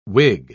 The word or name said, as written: wig